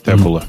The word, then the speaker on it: Tableau: Ты, ты, Tableau. Это вы так такое произносите, да? Табло. Ну, ну да, так, так они их называют. – Табло они называются.